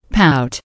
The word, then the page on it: pout: American English